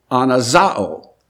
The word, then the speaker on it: anazao: Therefore, there is a specific word in the New Testament for revival, and that word is anazao. And it's spelled, I'll use English letters for you. It is spelled A-N-A-Z-A-O.